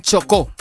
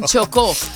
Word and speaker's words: Chocó: Mira, Beyoncé está donando $1,000,000 para las víctimas del terremoto en Colombia, en Chocó. ¿Lo dije bien? – ¡Eso! – En Chocó.